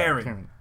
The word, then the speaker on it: Karin: Yeah, don't put the "een" in there. – Nah, don't hold that "een." My pronunciation is a little weird with her. Karen.